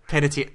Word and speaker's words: Panettiere: And it features Hayden Panettiere. – Panettiere, thank you. I could never pronounce her name.